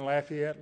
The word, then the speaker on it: Lafayette: We lived in Lafayette, Louisiana, and the people there say Lafayette. They don't say Lafayette. I know when I hear somebody's pronounce the name of the city, whether they're from there or not. They say Lafayette.